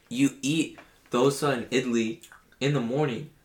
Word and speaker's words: idli: There's some Indian breakfast food called dosa and idli.